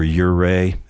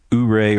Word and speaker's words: Ouray: That's the way it's pronounced. If you look, it's O-U-R-A-Y. So you would think it was Ouray or something like that. But if you're from Colorado, you pronounce it Ouray.